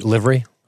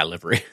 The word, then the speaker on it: livery: which is livery. I'm saying it wrong. – Yeah, livery. – Livery.